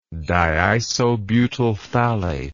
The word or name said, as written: diisobutylphthalate